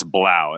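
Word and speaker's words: Blough: I think technically it's Blau. It rhymes with plow, as our Blau.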